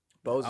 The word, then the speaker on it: Bose: Yeah, Bose. Yeah. – Bose.